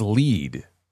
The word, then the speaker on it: Lied: Oh, it's pronounced Lead. Elfin Lead. Oh, man. Then it should be spelled that way.